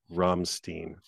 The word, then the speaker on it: Rammstein: It's pronounced Rammstein.